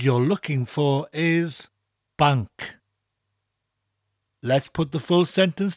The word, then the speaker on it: banc: And now we need the word for bank. Very simple, it's spelt B-A-N-C and pronounced the same as the English.